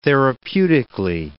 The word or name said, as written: therapeutically